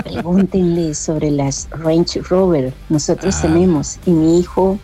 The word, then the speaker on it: Range Rover: Buena pronunciación, señora. Lo dijo muy bien, Range Rover.